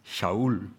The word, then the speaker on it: Shaul: His Hebrew name, Saul, in Hebrew pronounced Shaul.